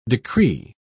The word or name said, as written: decree